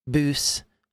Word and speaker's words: bws: with the W being an oo sound typically, boos makes sense.